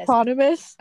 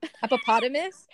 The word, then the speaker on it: eponymous: How do I say this? – Eponymous. – Hippopotamus. – No, eponymous.